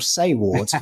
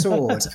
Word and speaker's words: Saward: Well, it's spelled Saward. And I when I first got in touch with Chatterbox, they said Kieran Sword. I said, that's a great name. And then they sent the details through, and I thought, Sword or Sayward, you know. So, but it's pronounced Sword, and Kieran's fantastic.